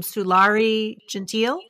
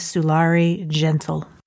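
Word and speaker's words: Sulari Gentill: So I'm going to talk about a thriller suspense novel that I read recently by an Australian crime writer named Sulari Gentile, I think is the way you say her name. I thought wrong. The way you pronounce her name is Sulari Gentle.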